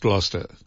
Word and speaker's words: Gloucestershire: Yeah, it's pronounced Gloucester. – Gloucester. Even so, it's got a Estershire. – Gloucester, so you can say Gloucestershire.